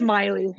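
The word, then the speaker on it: Meili: and Saskatchewan NDP leader Ryan Maley. Is that how do I pronounce it? – Maley? It's Miley.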